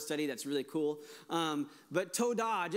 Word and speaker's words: Todah: It goes, an emphasis on the da. So it's Todah. That's how you say thank you. So God is like, ta-da. And then our response is Todah, right?